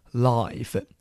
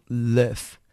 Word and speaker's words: live: 我们现场直播电视的直播，我们都叫做 live。这个时候要小心发音哦。住在什么地方？Live，形容词 live。